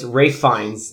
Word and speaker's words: Ralph Fiennes: It's Ralph. It's Ralph Fiennes. That's Ralph how you pronounce it.